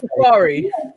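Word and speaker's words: Safaree: And also, is that how you say his name? – Sarafay. Safari. – Yeah, you said Mr. Sarafay. Like, what's wrong with you? – Safari. Do you know what? I put the R before the F.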